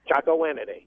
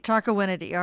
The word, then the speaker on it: Chocowinity: Calling us from Chocowinity, North Carolina. Am I saying that right? – Chocowinity. – Chocowinity.